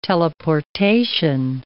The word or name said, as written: teleportation